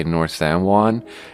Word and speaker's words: San Juan: I pronounce it wrong. I say North San Juan. But it's not, you're not supposed to overpronounce it and say it correctly and say North San Juan. You're supposed to say North San Juan. It's Juan, like Obi-Wan Kenobi.